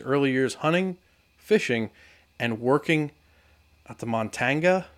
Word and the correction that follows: Montongo: How do you say it? – Montongo. – Montongo Game Farm.